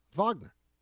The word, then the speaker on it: Wagner: So that's, it is Wagner, right? – Is it? – Yes, it's Wagner, like the composer. – Is that true? – Yeah, Wagner.